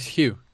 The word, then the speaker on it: Hugh: Is it pronounced how? Is that right? – Nah. it's Hugh. – Hugh. – Like H-U-G-H, but it's the Welsh way, man.